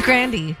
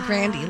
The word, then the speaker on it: Grande: Ariana Grande. – Mm-hmm. Grande. – It's Grandy. – Grande. – There's an actual interview where... – It's a family name. – Yeah, it's pronounced Grandy.